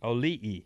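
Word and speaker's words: Auli'i: Oli'i.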